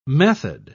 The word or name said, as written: method